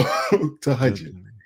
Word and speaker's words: Tahajjud: I called it Tajahoon. I didn't know how to pronounce it. So Tahajjud.